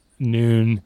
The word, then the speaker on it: Noon: So, Since being out here, sort of transitioning your career, working for Noon, is that am I pronouncing that right? – Yeah, that's right. – Noon? – Just like okay. – the time of day. – Like the time of day.